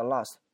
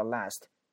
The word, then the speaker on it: last: 首先这个 last 这个单词两种发音，第一个叫 last，第二个可以叫 last，一个是 a 的音，一个是 a 的音， last last 都可以。